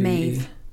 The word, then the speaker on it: Maeve: it's pronounced Meave, that, isn't it? M-A-E-V. – Maeve. – It's pronounced Maeve? – Mm-hmm. – Meave 2. Meave C2. – You said it wrong anyway. – Maeve? – Maeve. – Oh, sorry. Maeve.